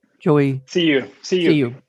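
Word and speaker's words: Joey Siu: And I think I'd be remiss if I didn't mention, is it Joey Tsui? How do you say that? Joey? – See you.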